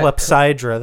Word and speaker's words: clepsydra: Oh, yeah, clepsydra. – That's another name for it. – Oh, yeah, I mispronounced it when I read it earlier.